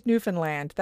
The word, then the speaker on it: Newfoundland: And like you said in your beginning part, like I said, where Newfoundland is so famously mispronounced as Newfoundland or Newfoundland, yes. Or I guess other provinces are that way too. So that is the first thing that really jumped out at me. – Understand Newfoundland. That's how you know how to say it.